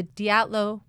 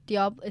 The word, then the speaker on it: Dyatlov: This is the story of the Dyatlov Pass. – It's pronounced differently than Diablo. It's not. Why did I think it was Diablo Pass? – Shed thought that too.